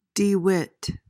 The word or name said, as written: DeWitt